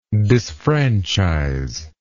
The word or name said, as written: disfranchiser